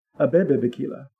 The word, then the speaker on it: Abebe Bikila: So Adebe Bikila... Abebe Bikila. – Spell it. – A-B-B-E-B and is B-I-K-I-L. – He's an Ethiopian marathon runner.